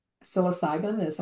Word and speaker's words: psilocybin: So, if I'm understanding you correctly, if you were using psilocybin, am I saying that correctly? – Yes, Okay. – perfect.